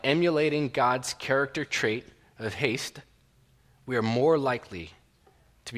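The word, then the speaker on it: hesed: Now, that word that I used, haste, it's spelled H-E-S-E-D. H-E-S-E-D. It's a Hebrew word, but it's pronounced like haste, like speedy, fast, right, when you're rushing.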